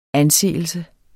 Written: anseelse

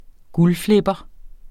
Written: guldflipper

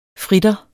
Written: fritter

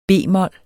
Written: b-mol

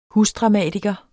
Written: husdramatiker

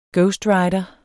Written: ghostwriter

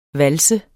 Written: VALSERNE

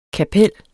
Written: kapel

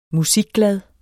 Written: musikglad

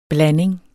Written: blanding

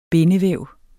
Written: bindevæv

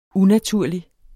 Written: UNATURLIGT

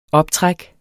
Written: optræk

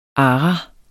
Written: ara

